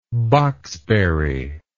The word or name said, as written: boxberry